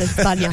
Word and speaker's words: España: You know, it's actually pronounced España.